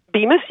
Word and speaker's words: Bemis: Do I have the pronunciation of Polly's last name even close to correct? – Bemis? Yes, absolutely.